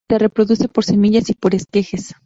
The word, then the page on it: semillas: ispanyolca